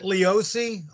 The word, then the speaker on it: Leoce: We pronounce it Leoce, although it's kind of spelled Leoce. half the family spells it my way. Half the family spells it L-I-O-C-E.